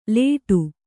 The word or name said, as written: ಲೇಟು